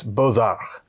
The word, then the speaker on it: Bozard: I had to look up how to pronounce that. I was a little bit worried if it was going to be like the traditional French pronunciation or if it would be a more Americanized one. But it's Bozard.